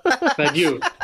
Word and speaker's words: Badiou: Badiou.